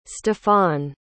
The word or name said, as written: stefon